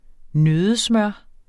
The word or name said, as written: nøddesmør